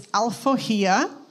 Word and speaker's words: Alforria: So the name of the coffee, I'm going to help you pronounce it. It's Alforria. Alforria in Portuguese, or in Brazil specifically, I guess the closest word that you would have in English is emancipation.